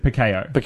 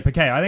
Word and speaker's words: Pacheco: He corrected you on the pronunciation. – I think I said Pacheco. – And he said Pacheco. Yeah, Pacheco. – It's a Spanish name.